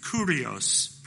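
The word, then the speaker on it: kurios (κύριος): So getting help by Pastor Brian Borgman this week, our English word Lord is kurios, pronounced in the Greek.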